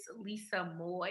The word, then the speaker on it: Lisa Moy: You can follow me at It's Lisa Moy, and my last name is spelled M-O-Y.